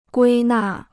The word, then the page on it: 归纳: guī nà